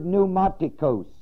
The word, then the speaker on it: pneumatikos: The word spiritual is the word pneumatikos. Pneumatikos. It's spelled P-N-E-U-M-A-T-I-K-O-S.